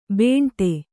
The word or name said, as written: ಬೆಂಡೆ